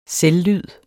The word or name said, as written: selvlyd